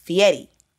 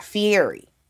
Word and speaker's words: Fieri: But I had to go back and re-record because his last name is pronounced Fieri. It's not Fieri.